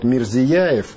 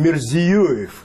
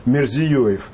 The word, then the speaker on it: Мирзиёев: У нас обычно его называют Мирзияев, что вызывает вопросы и даже напряжение в экспертном сообществе Центральной Азии. То есть ведущие эксперты говорят, почему путают фамилию? Он Мирзиёев.